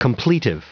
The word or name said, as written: completive